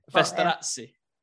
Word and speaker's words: Festorazzi: My husband would say Festorazzi. – Festorazzi. – Yes, that's how he would pronounce it.